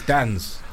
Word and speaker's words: DNZ: I'm so dumb. Realistically, it's Dans.